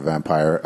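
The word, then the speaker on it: Vampyr: When I first read it, I thought it was Vampire. – It does look like it's Vampire. Yeah, but they pronounce it Vampire.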